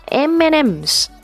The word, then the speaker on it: M&M's: 如果快一点的话就是 H&M、啊、同样的概念 M&M's、啊、这个巧克力也常常会被大家念成 MM 巧克力、啊、也是错的，不能忘记了、啊、这个当中这个符号它是代表的是 and、啊、 M&M's、啊、巧克力。